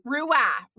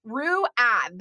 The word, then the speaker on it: ruah: What the Israelites did in this case, when it talks about they shouted with a great shout, it's the word ruah in the Hebrew, ruah, ruah.